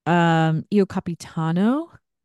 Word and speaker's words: Io Capitano: Io Capitano.